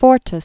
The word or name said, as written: Abraham Fortas